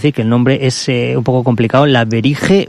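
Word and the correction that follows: Vrije: Universiteit. ¿Lo he dicho bien? – Frey, como libro.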